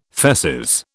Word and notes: feces: British English

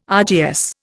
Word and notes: RGS: Indian

